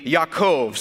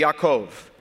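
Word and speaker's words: Yaakov (יעקב): He calls me Jacob, but the way that you would pronounce that in Hebrew would be Yaakov. Say Yaakov.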